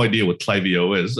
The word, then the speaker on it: Klaviyo: Let's start with Klaviyo.